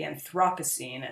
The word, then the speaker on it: Anthropocene: the the Anthropocene or the Anthropocene, as I've heard it pronounced in England.